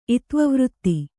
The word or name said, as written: ಇತ್ವವೃತ್ತಿ